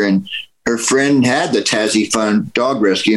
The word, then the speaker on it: Tazzy: Am I saying that right? It's Tazzy? – Yeah, T-A-Z-Z-Y, Tazzy Fund.